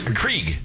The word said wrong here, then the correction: Keurig: Keurig. Yeah. You don't even know how to say it.